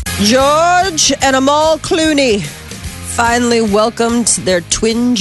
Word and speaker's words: Amal: Amal.